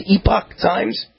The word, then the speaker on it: Epoch Times: I see a headline right here in, I think you pronounce it Epoch Times. Do you know if that's the way they pronounce it, Epoch Times? – I think it is, yeah.